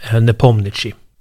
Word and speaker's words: Nepomniachtchi: Jag har försökt lyssna på hur det låter när ryssar faktiskt uttalar honom. De säger ofta Nepo, men de säger också Nepomniachtchi.